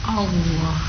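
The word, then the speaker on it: Allah (الله): Allah. – A. – Allah. – Yes. Ya, jadi A itu tipis, Lohnya itu yang tebal karena huruf lamnya yang ditebalkan. Fakhimillama minismillah ya. Jadi cara bacanya Allah.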